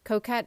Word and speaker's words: Choquette: Coquette is spelled Choquette, and that is called The Psychic Pathway.